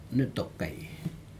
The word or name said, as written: nitokai